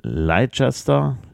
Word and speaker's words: Leicester: Wurde als Kind in Leicester, so wird es ausgesprochen.